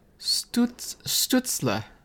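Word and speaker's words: Stützle: She also missed Tim Stutzel? – No, I said his name wrong. – Stutzler.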